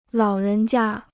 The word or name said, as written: 老人家